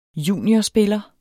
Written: juniorspiller

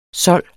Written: sold

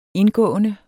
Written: indgående

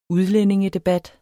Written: udlændingedebat

